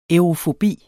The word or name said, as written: aerofobi